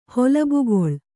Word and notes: ಹೊಲಬುಗೊಳ್: Kannada

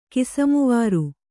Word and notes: ಕಿಸಮುವಾರು: kannada

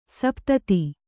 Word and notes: ಸಪ್ತದ: saptada

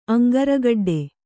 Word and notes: ಅಂಗಾರಗಡ್ಡೆ: aŋgāragaḍḍe

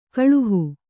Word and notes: ಗಳುಹು: Kannada

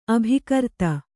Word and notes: ಅಭಿಕರ್ತೆ: abhikarte

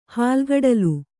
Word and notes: ಹಾಲ್ಗಡಲು: hālgaḍalu

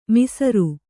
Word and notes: ಮಸಾರೆ: masāre

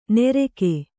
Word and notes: ನೆರೆಕೆ: nereke

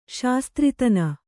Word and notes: ಶಾಸ್ತ್ರಿತನ: Kannada

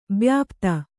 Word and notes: ಬ್ಯಾಪ್ತ: byāpta

